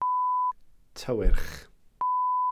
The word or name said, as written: tywyrch